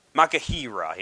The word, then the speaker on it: machaira: And then in the Greek, the word used for sword is Makahira, yes. I even with the parentheses showing how to pronounce it, it's hard to pronounce these. I don't speak Greek, so spelling for that is M-A-C-H-A-I-R-A. Ma, chair, and then That's how you spell it.